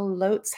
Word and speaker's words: Lotz: Now, if you are a Franklin local, you probably, like me, have mispronounced the name of this place for, oh, say 20 years, just like I have. L-O-T-Z, it's Lotz. It has been Lotz to me forever, but I stand corrected.